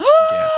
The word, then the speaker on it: GIF: I'm diehard GIF, but in researching it, we did find that in the original documentation of like the GIF paper, they mention specifically the peanut butter company. – Which is JIF. – Which is GIF. And they said it's pronounced like the peanut butter.